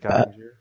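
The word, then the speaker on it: Oettinger: Ottinger? How do you say his name? – Jake Ottinger, yeah.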